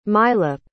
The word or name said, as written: milap